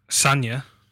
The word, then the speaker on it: Sagna: Vardy, Kane, Mane, Mahrez, Eriksen, Mata, Smalling, Kolarov, Sagna, Gomez. Nice team. – Sanya. – Yeah, you pronounced the G. You know, you said Sagna.